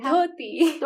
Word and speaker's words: dhoti: dhoti. Dhoti. – I still can't do it. Almost. Oh, it's the DH. Yeah.